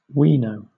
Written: wino

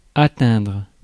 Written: score

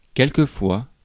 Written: quelquefois

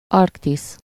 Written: Arctic

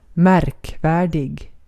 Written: märkvärdig

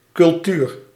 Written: cultuur

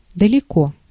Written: far

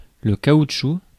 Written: rubber

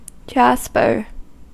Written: yeşim

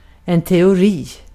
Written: teori

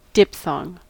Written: diphthong

